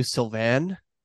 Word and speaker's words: Sylvain: My French name is Sylvain, at which Sil means lash and vin means wine.